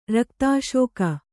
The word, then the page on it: ರಕ್ತಸೇಕ: rakta sēka